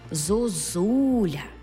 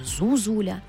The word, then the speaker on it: зозуля: Наведу приклад. Зозуля. Як падає наголос? Зозуля. Ми чітко чуємо другу «у». А от першу «у» ми, як і іноземці, не будемо знати, як писати. Зозуля чи, може, зузуля.